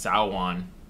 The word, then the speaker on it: Samhain: But it's actually pronounced Samhain. But yeah, they named him Sam after it's like Samhain, but cut short.